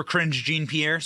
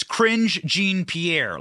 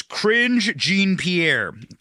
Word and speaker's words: Karine Jean-Pierre: You're about to see, we play a lot of cringe alerts for Karine Jean-Pierre. Sorry, I mispronounced her name. It's Karine Jean-Pierre. Let me say that. Let me read that correctly. Let me read it as she would. It's Karine Jean-Pierre.